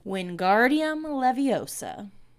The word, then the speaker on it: Wingardium Leviosa: Wingardium Leviosa.